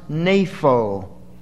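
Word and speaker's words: napho (νήφω): The word is napho. If we were to spell it in English letters, it would spell something like N-E-P-H-O. And you'd put a little long mark over the E and another one over the O. Pronounced napho.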